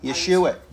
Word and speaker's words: Yeshua: And Yeshua. Jesus' name is Yeshua. That's how you say his name in Hebrew. There are no J's in Hebrew.